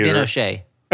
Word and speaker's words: Binoche: Binoche.